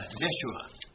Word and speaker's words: Yeshua: Yeah, Yeshua. Thank you. Yeshua, which is, it sounds like Yahshua, it sounds like Yahweh, it sounds like, you know, all of these names.